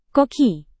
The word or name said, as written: koki